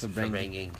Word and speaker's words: Verbringung: We're doing it a little short today because we're tired from 2 weeks of Verbringung. – Yeah. – Is that how you say it? – Yes, Verbringung.